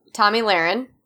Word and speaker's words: Tomi Lahren: Is that how you say her name? – Nope, that's not how you say her name. But how do you say it? – Tomi Lahren.